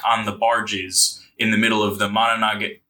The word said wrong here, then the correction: Monongahela: Fuck, I went through the goddamn pronunciation before the episode. The Monongahela.